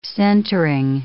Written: centering

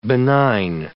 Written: benign

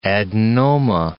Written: adenoma

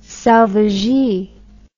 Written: selvagee

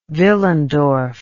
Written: Willendorf

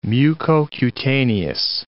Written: mucocutaneous

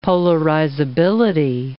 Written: polarizability

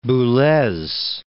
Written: Boulez

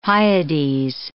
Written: Hyades

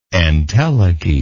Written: entelechial

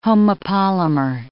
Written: homopolymer

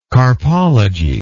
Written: carpologist

